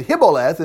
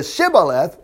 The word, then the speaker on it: Shibboleth: But if he couldn't pronounce the H and said Hiboleth instead of Shibboleth, remember, I'm drunk writing this.